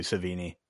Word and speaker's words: Sevigny: I love the way you pronounce Chloe Sevigny. – Sevigny? – Like a beignet?